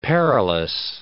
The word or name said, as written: perilous